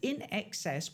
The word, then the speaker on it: INXS: The INXS, pronounced INXS, was inspired by a combination of the British band XTC and the Australian jam brand IXL.